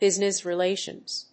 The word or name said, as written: business relations